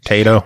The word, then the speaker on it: Taito: Elevator Action is an action game released by Taito in 1980. Is that how you say it, Taito? – Taito.